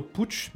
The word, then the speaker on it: Puig: Ah, le directeur euh, euh, de, de, de, chez Honda Racing, non, Honda Repsol Racing, donc le, team, s'appelle Alberto Pucci, mais ça s'écrit Puig si jamais.